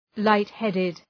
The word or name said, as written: light-headed